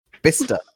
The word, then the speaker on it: Bicester: Bister, as in the town. – I've never heard of Bister. – It's spelled Bicester.